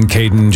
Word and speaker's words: Caden: And you said his name is going to be Caden? Anything rhyming with Aiden's very popular right now. Aiden, Caden, Jaden.